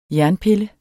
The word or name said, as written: jernpille